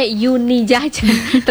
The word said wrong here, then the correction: Unit Jajan: ternyata Unit Jajan,